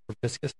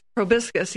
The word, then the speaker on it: proboscis: Proboscis. – Proboscis, yes.